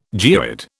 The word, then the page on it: geoid: British English